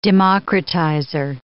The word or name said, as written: democratizer